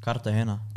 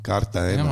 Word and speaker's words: Cartagena: Cartagena. – Cartagena.